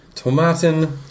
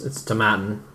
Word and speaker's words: Tomatin: Established in 1897, Tomatin, to rhyme with satin. – Which means it's Tomatin.